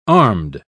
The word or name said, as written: armed